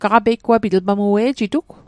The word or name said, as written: peigwapit